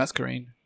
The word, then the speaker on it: Masquerain: I think it's Masquerain. – Masquerain? I'm always so bad at pronouncing Pokemon names. God. And I love the series. – It's like Masquerade.